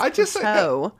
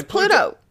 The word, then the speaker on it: Pluto: You insist on calling it, the way that you pronounce it is Pluteau. As if it's like a French channel. Pluteau. – I don't say it like it's a French channel. I just say Pluto. I say Pluto. – It's Pluto. Pluto.